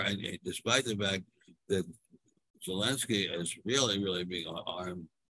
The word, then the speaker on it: Zelensky: whose name I can never pronounce. – Zelensky, thank you.